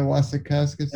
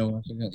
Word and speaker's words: Wasikowska: She looks like Anna Wyomikowski a little. Or Anna Wyomikowski, yeah. Did I say her name right? – Anna Mia Wasikowska. – Anna Mia Wasikowska, yeah.